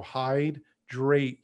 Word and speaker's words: Hydreight: And remember, when you're looking up that app, when you're typing in Hydreight, uh, the eight part is like the number E I G H T. So Hydreight, you want to make sure that it's spelled correctly.